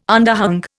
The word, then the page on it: underhung: Indian